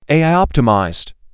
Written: AI-optimized

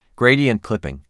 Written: Gradient clipping

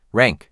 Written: Rank

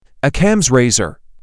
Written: Occam’s razor

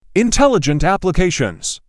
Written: Intelligent applications

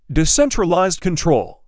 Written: Decentralized control

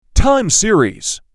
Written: Time series

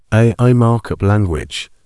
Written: AI Markup language

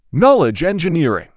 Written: Knowledge engineering

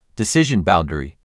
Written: Decision boundary

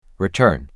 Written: Return

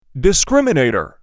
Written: Discriminator

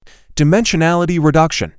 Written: Dimensionality reduction

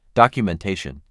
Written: documentation

